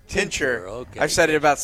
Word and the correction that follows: Tincher: Emma Tincher. See, now I got it.